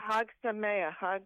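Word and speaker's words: Chag Sameach: was it a Chag Samich? Is that, am I pronouncing that correctly? – Hag Sameach. And of course,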